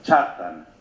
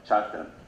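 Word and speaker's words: Kjartan: that his name is pronounced Chartan, Chartan, a Norse name, and uh, uh, pronounced like that. Okay, Chartan,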